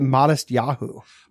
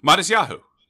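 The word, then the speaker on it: Matisyahu: Who somehow you pronounce it Modest Yahoo. – Modest Yahoo. – Which I had no idea how that was pronounced.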